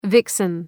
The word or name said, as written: vixen